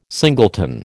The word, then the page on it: singleton: American English